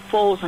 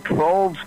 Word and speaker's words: Foles: I would, since Wentz is so injury-prone, I would go with Nick Foles, but you don't know how he's going to do, but I'm not Doug Peterson. So. – Yeah, right. His name rhymes with Sproles, so I like it when Sproles Yeah.